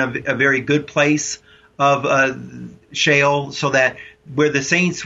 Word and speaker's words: Sheol: Yes, we know that they went, Matt, to Sheol. S-H-E-O-L is how it's spelled in English, the Hebrew word, Sheol.